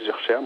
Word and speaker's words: Zurcher: Et au sujet du scénario, en fait, c'est Muriel Zurcher, c'est comme ça qu'on prononce ? – Voilà, la scénariste est Muriel Zurcher.